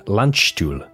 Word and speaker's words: Landstuhl: The following year, in 1961, an 18-year-old Gary was transferred to join the 46th Army Surgical Hospital in Landstuhl, which is in West Germany. Dan, did I say that right? – You nailed it, boy.